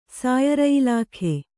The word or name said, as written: ಸಾಯರಯಿಲಾಖೆ